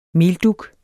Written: meldug

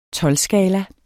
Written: tolvskala

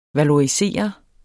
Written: valorisere

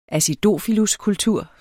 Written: acidofiluskultur

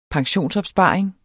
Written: pensionsopsparing

